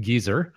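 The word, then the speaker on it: Geysir: But that particular one, Geyser, or as it's pronounced in Iceland, Geyser, is the first known geyser discovered by people.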